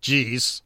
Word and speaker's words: Jiz: However, many of the tweets reference the fact that his first name is Jiz, which is reportedly pronounced more like Jeez.